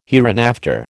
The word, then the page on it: hereinafter: American English